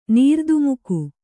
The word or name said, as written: ನೀರ್ದುಮುಕು